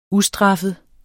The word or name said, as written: ustraffet